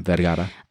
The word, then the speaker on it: Vergara: So the first couple is Sofia Vergara. Did I say that right? – Yeah, Vergara.